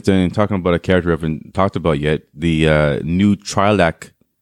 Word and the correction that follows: Twi'lek: That's not right. – That pronounced Twi'lek. Right? – Twi'lek. Thank you.